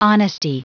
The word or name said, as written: honesty